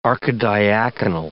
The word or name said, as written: archidiaconal